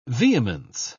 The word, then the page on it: vehemence: British English